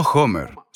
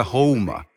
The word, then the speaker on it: Houma: No Homer, se pronuncia Houma, como los indios Houma.